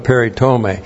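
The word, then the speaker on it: Peritome (περιτομή): P-E-R-I-T-O-M long E. Peritome.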